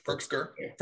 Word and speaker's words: Firkser: That one, Firkser.